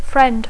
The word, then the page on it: friend: English